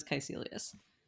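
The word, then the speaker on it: Kaecilius: My understanding is that it's, I was wrong. I thought it was a hard C, it's a soft C, so it's Caecilius.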